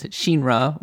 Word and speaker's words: Shinra: Well, it's because it's she, as in she, like in Japanese, Shinra would be how you would pronounce it in the original Japanese.